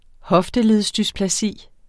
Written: hofteledsdysplasi

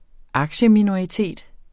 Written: aktieminoritet

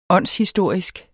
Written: åndshistorisk

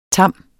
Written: tam

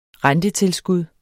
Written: rentetilskud

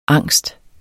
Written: angst

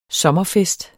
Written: sommerfest

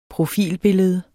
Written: profilbillede

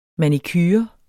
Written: manicure

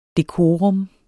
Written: dekorum

